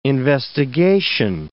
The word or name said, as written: investigation